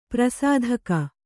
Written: ಪ್ರಾಸಾದಿಕ

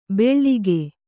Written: ಬೆಳ್ಳಿಗೆ

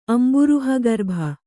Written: ಅಂಬುರುಹಗರ್ಭ

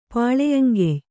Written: ಪಾಳೆಯಂಗೆಯ್

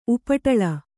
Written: ಉಪಟಲ

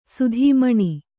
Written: ಸೌದಾಮನಿ

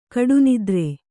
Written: ಗಾಢನಿದ್ರೆ

